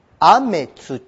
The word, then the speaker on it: 天地: 天地って読む。これが音読みでございますね。音読み。じゃあ訓読みで言うと何て言うの？雨土って読むんです。雨土って読むんです。これが訓読みなんです。